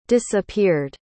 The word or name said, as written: disappeared